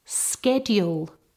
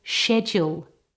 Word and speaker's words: schedule: This word can be pronounced as schedule or schedule. I say schedule, but both are correct.